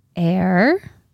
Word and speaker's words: Aire: Well, so she posted a cute little photo with his name, which is Air. She did confirm the pronunciation is air, even though it's spelled A-I-R-E.